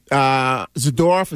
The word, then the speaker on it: Zadorov: And even the Zadorov, is that how you say his name? – Yes.